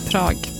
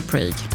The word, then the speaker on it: Prag: Tydligen så uttalas Prag likadant på engelska som på svenska typ. Svårt. Prag. Man vill ju säga Prague.